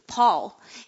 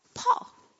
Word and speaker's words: Paul: Like the last one that I learned was how to pronounce the right way, Paul. And then my teacher said, you know, this is a tricky word. You say like a call, ball, Paul.